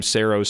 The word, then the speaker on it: Saro: The name Saro Cider, spelled S-A-R-O, is pronounced like the word arrow.